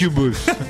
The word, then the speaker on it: Incubus: Incubus. Así se pronuncia.